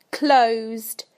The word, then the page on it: closed: British English